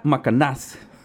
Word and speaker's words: Macanás: El nombre de la señora es Teresa Macanás acento en la última A, para que no se...